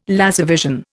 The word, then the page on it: laservision: Indian